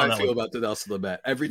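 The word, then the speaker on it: Dinelson Lamet: So the 3-1 game, this one's going to be a little less painful because DeNelson Lamet. Did I say that right? DeNelson Lamet. – Yes, but oh man, don't that name.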